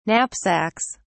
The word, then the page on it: knapsacks: British English